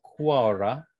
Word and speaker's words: Qawra: near in a place called Aura, but it's spelt with a Q, Quara, Q-A-W-R-A.